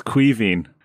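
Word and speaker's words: Caoimhín: So it's Kevin. Right. Like, how do you get that like from that? – I mean, it's, for those who don't know, it's spelt Kaomin. So. – You just do, okay, Billy? You just do. So the MH is a V sound, if we're being technical about it.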